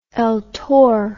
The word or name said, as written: eltor